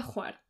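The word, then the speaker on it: Hvar: Y se pronuncia Juar.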